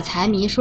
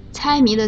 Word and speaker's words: 财迷: 你呀，你把财迷说成了猜谜，一个字的声调错了。猜谜的猜是一声，财迷的财是二声。